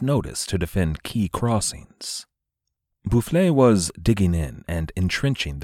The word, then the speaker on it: Boufflers: Marshal General Boufflers, and thanks to those of you who corrected my pronunciation of Boufflers, the marshal was securing France's position all along the Rhine.